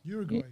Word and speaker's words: Uruguay: I heard Uruguay, or that's how you say it? – Uruguay, yeah.